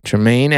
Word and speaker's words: Tremaine: Let me say his name. Is it Tremaine? Yeah, Tremaine Edmonds.